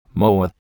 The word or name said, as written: moa